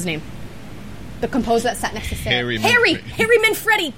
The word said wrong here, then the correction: Manfredini: Manfredini. – Manfredini. He told me how to pronounce that so many times.